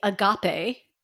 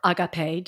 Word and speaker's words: agape: And that, my friends, is the agape, or agape, depending how you want to pronounce it, love that Christianity is so on about.